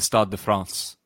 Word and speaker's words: Stade de France: Good news, it's actually at the Stade de France.